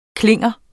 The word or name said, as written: klinger